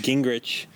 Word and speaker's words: Gingrich: Gingrich.